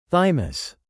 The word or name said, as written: thymus